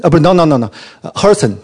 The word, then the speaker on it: Herzen: Herzen. Herzen. – Alexander Herzen.